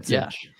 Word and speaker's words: Micic: Trying to pronounce that correctly now that we have that. Micic.